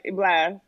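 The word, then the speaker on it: Blxst: Am I saying it right? Blixter Blast. – Oh, it's got to be Blast, right? – My bad. – Yeah, you're saying it right. Blast.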